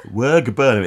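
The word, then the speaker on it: wearg burna: Wergburner. It's spelled W-E-A-R-G, so we think that's Werg, and Burner, B-U-R-N-A.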